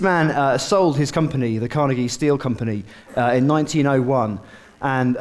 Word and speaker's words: Carnegie: This is Andrew Carnegie, or Carnegie for the pronunciation that Harry said was right.